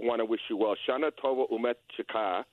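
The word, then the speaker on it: Shana Tova Umetuka: It's Shana Tova Umetika. The emphasis is on the last syllable.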